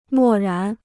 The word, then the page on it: 漠然: mò rán